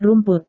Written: rumput

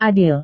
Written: adil

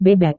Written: bebek

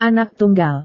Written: anak tunggal

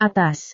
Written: atas

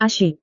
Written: asyik

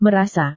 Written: merasa

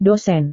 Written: dosen

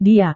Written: dia

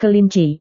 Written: kelinci

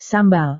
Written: sambal

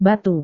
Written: batu

